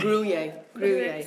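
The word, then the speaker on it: Gruyère: Cheese on toast is a slice of bowl with some, is it Gruyere? How do you say that word? – Don't look at me. – Gruyere. – Sorry, thank you.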